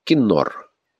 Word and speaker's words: кинор: При Моисее слово «кинор» произносилось как «кинару», а во времена Давида оно записывалось «кенер» и произносилось «кинор».